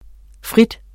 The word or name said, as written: fritter